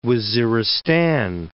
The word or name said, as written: Waziristan